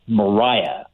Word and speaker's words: Maria: this woman in Buffalo by the name of Maria Halpin. It's likely, actually, as a side note, that her name was probably pronounced Mariah under the patterns of the day.